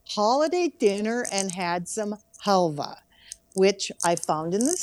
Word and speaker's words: halva: And this time, you want to talk about, and I'm not sure, is it halva or is it halva? – That's, we pronounced it halva.